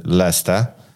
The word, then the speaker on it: Leicester: Skoro Leicester czyta się Lesta, to jak się czyta ten Worcestershire sauce?